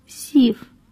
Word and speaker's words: СИФ: CIF 是英文成本加运费保险费价格的缩写，俄语读成 СИФ.